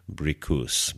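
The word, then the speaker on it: Bricusse: You may know it by Nina Simone, but it's actually written by Anthony Newley and Leslie, and I'm going to guess that the pronunciation of the last name is Bricus, because I speak French, and that's how it's spelled in French.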